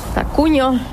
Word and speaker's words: TakUnio: Czyli on jest Tak i duże Unio razem pisane. Czyta się Tak Unio.